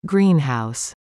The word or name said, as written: greenhouse